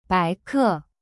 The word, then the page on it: 白客: bai ke